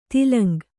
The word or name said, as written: ತೇಲುಂಗ